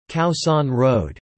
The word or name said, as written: Khaosan Road